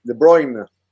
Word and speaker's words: De Bruyne: lo he dicho bien, ¿no? Kevin De Bruyne. – Hay que decirlo con, con hoy enfadado, ¿no? De Bruyne. De Bruyne. – Ahí está.